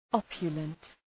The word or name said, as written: opulent